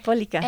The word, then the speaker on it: Polika: So another question I wanted to ask was, would you mind talking about like how you founded like Polika, is that how you say it? – Polika.